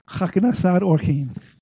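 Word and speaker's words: Chaknasar Orchim (הכנסת אורחים): So you see that CH, it's not Ch, it's Cha. Orchim, in this case, He. Chim. Chaknasar Orchim.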